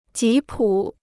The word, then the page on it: 吉普: jí pǔ